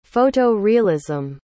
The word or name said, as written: photo-realism